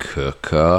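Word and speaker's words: Quooker: Welcome to the Dear Lovejoy podcast, sponsored by Cooker, spelt quicker, pronounced Cooker.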